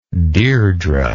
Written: Deirdre

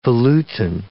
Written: volutin

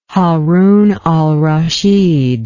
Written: Haroun al Raschid

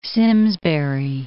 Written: Simsbury

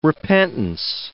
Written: repentance

